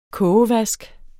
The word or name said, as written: kogevask